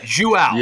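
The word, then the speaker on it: João: I think it's Joao. I think it's like Joao. – Joao. Okay. – But is the last name Fonseca or Fonseca? – Yep. – You got it right.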